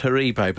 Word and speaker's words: Haribo: It's a European company. You will find it is actually pronounced Haribo, but less about that.